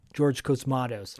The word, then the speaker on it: Cosmatos: I'm butchering his last name, but Costamos, I think is his name. Regardless, you know, that movie had so much going for it. Oh, Cosmatos, sorry. George Cosmatos.